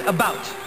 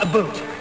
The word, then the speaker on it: about: I speak English and French, not American, and I pronounce it about, not a boot.